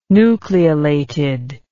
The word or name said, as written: nucleolated